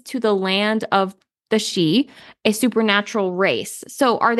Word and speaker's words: sidhe: It's pronounced she. – She. Okay. Mm-hmm. That's what I looked up is not bad. – Like banshee. It's a form of fairy.